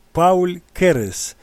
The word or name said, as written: Paul Keres